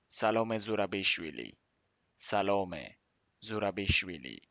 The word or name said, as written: სალომე ზურაბიშვილი